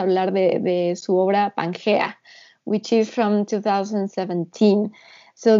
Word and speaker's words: Pangea: Am I pronouncing it correctly? Pangea. – You are.